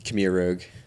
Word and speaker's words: Khmer Rouge: Khmer Rouge? – Khmer Rouge.